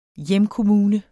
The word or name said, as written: hjemkommune